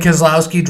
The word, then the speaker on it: Keselowski: Keselowski. – Is he like the buck tooth guy that wants to fight everybody in the pit? – Yeah, he came out at Bristol one time and said, Brad Keselowski, driver of the number 2 Miller Lite, Kyle Busch is an ass.